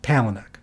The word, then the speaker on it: Palahniuk: But anyway, Palahniuk, that's how you say it.